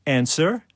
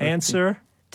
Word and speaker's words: answer: Or answer. – W. – W, yeah. And Neil.